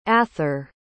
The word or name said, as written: athor